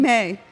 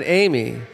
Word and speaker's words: Aimee: Aimee. – Aimee. – Yeah. – It's not Amy. – No, it's Aimee. It's Aimee. French.